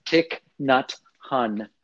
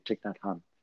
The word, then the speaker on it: Thich Nhat Hanh: Thich Nhat Hanh. I believe yes. that's how it's said here in California, Thich Nhat Hanh.